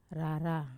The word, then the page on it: rara: wamesa